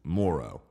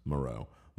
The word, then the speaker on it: Moreau: it's Moreau, that's how we pronounce Moreau, Moreau.